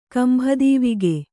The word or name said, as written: ಕಂಭದೀವಿಗೆ